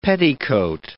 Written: petticoat

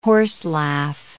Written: horselaugh

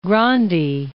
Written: Grandi

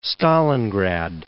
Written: Stalingrad

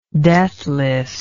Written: deathlessness

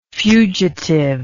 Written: fugitivity

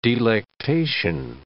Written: delectation

